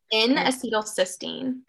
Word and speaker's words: N-acetylcysteine: N-acetylcysteine.